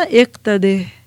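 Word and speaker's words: اقتده: جب کوئی فعل امر زیر سے ختم ہو رہا ہو تو پرونانسیشن یعنی تلفظ کو واضح کرنے کے لیے ہا لگا دی جاتی ہے. آسان ہو گیا نا اقتدے.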